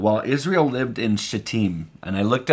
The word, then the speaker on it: Shittim: and it makes them giggle because it doesn't look like Shittim. But that's how you say it.